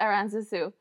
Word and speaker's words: Aranzazu: So in Spanish, it's Aranzazu. In English, it's Aranzazu.